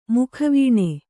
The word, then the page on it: ಮುಖವೇಣು: mukha vēṇu